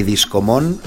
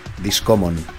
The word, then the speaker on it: Discommon: Ni Discomon, ni Discomun, ni Discomun. Discommon.